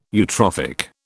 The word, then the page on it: eutrophic: British English